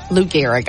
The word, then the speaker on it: Lou Gehrig: Lou Gehrig.